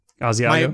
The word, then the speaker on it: Asiago: My canvas is five cheese asagio, whatever the fuck." – Asiago. – My canvas. Yeah, that's the word I butchered mercilessly.